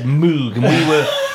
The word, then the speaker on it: Moog: I think the American pronunciation appears to be Moog,